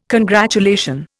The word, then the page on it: congratulation: Indian